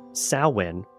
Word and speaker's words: Samhain: The holiday can be traced back primarily to Ireland and the Celtic celebration of Samhain. I worked on that pronunciation, guys. – Good for you. – Because it's Irish, so it's not spelled like that at all.